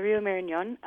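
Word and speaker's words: Marañón: Yeah, it's the Rio Marañon, and it's an amazing river.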